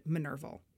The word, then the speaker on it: Minerva: and Illuminated Minerval. Minerva, Roman goddess of wisdom, or Minerva, as my Latin teacher would be yelling at me to pronounce it, was the symbol of their organization.